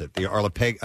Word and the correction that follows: archipelago: the archipelago. – Archipelago.